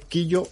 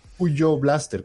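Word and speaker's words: Qyo Blaster: y hay uno que me quedó en la memoria, que es un juego chileno del estudio Robot Black Hat, se llama Killo Blaster, se escribe Q-Y-O Blaster. Se puede decir Quillo o Cuyo Blaster, como quieran pronunciarlo.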